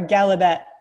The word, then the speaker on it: Gallaudet: It's a very fancy university, but can you pronounce it for me, please? – Sure. Gallaudet.